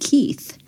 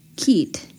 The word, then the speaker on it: Keith: And I said, Keith. And he kept saying, oh, Keith. Keet. I said, no, you have to put your tongue under your front teeth and then blow air through it.